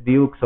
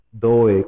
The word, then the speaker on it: dough: Eh, siempre me regaña, dice, no es de Dukes o de Oaks, dice, se pronuncia Doe, Cookie Doe.